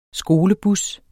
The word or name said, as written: skolebus